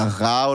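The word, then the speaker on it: Gà: Und dann äh, es halt einmal Ga und einmal, oder Ra oder so wird's ausgesprochen, haben wir gelernt. Und einmal Bo. Ja. Und das ist halt, also eins davon, also Ra, also G-A geschrieben.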